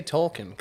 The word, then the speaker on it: Tolkien: Just say Tolkien, because I know it's Tolkien. – For sure. Isn't Tolkien? Like, isn't there an I in there? – Nope. There is. There is. It's Tolkien. But... It's pronounced Tolkien.